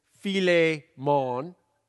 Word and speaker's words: Philemon: Chick-fil-A Monday, or take out the chick, and it's Philemon.